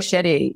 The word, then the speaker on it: Jay Shetty: With was it Jay Shetty? Is that pronounce right. Yeah, yeah Jay yeah. Shetty.